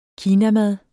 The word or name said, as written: kinamad